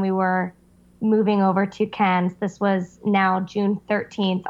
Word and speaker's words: Cairns: So you get to Cairns.